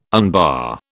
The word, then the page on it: unbar: British English